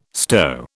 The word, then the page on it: stow: British English